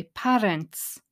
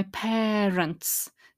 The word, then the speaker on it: parents: And parents there is a really commonly mispronounced word. It's on my 100 most mispronounced words list. People tend to say parents. If you say parents, then you won't hear what she says, my parents, that air sound.